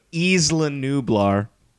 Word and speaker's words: Isla Nublar: We went to Isla Nuber. – It's pronounced how you pronounce it. – Isla Nublar.